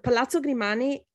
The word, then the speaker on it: Palazzo Grimani: So that's, I think that's, is Palazzo Grimani, for me, is one of the buildings in Venice that must be visited, in my opinion, with a guide.